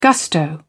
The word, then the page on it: gusto: British English